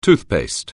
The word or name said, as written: toothpaste